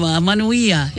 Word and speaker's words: Manuia: Oh, Manuia. Is that right? Did I say that correctly? – You said it perfectly.